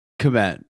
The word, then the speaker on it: Kmet: How do we pronounce his last name? It's it's Kmet.